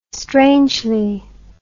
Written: strangely

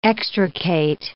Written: extricate

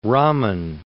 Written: Raman

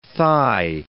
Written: thigh

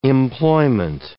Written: employment

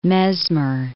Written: Mesmer

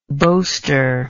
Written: boaster

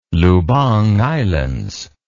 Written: Lubang Islands

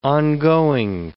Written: ongoing

